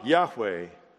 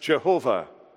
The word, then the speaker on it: Yahweh: When you see capital or uppercase letters, that name is the English form of the Hebrew word Yah, Yahweh, sometimes mispronounced Jehovah.